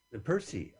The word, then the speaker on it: Percy: Officer Tully here, you just corrected me to say it properly. Is he Officer? – Percy. Officer Percy. Oh, it's a Percy.